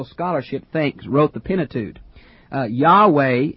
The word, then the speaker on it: Yahweh: Now, it's Yahweh seems to be a better pronunciation.